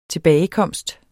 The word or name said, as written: tilbagekomst